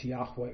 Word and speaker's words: Yahweh: The consonants are YHWH, sometimes pronounced Yahweh.